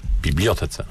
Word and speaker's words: Bibliotece: Bibliotece, to no dobrze, tak, dobrze zaakcentuję.